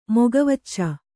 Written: ಮೊಗವಚ್ಚ

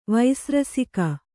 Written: ವೈಸ್ರಸಿಕ

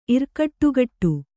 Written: ಇರ್ಕಟ್ಟುಗಟ್ಟು

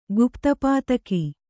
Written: ಗುಪ್ತಪಾತಕ

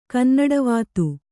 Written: ಕನ್ನಡವಾತು

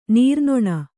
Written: ನೀರ್ನೊಣ

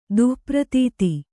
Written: ದುಃಪ್ರತೀತಿ

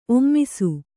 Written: ಒಮ್ಮಿಸು

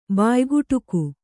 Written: ಬಾಯ್ಕುಟುಕು

